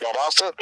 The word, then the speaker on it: Valdosta: Am I even close? – That's correct, Valdosta. Close enough.